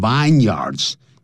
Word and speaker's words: vineyards: It is thought, 一般认为 that the vineyards of the Cape, 不要念成 vineyards, 叫 vineyards。